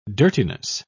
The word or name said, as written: dirtiness